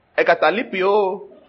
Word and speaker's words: egkataleipo: The word there, forsaken, is E-G-K-A. T-A-L-E-I-P-O. Ekatalipio. Ekatalipio. So, E-G-K-A-T-A-L-E-I-P-O.